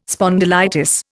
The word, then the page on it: spondylitis: Indian